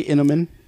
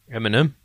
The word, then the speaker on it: Eminem: How do you say Inamin? – Inamin? – Inamin, the come rapper. – On, fundamentals. – Eminem.